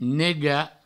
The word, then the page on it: negar: nega